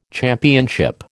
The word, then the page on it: championship: American English